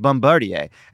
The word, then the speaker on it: Bombardier: Continued Gaetz, "I get my joy from saying things like, it's actually pronounced Bombardier.